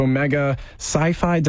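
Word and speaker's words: Omega Psi Phi: So going into the Phi Gamma Gamma chapter of Omega Sci-Fi, did I pronounce that right? – You did.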